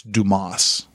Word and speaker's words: Dumas: And the guy leans forward and goes, it's Dumas. And you realize, oh, okay, it's about D-U-M-A-S, which if you're from the Texas Panhandle, you pronounce that Dumas instead of Dumas, but still.